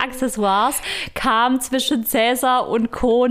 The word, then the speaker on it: Accessoires: Aber das stimmt so nicht. Denn eigentlich wird es Accessoires ausgesprochen, denn äh, du weißt es vielleicht auch, du hattest, glaube ich, Latein in der Schule.